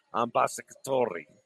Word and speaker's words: Ambassadori: Roll the R. Ambassadori. And remember to pronounce the Italian A as an open A, ah, as in father.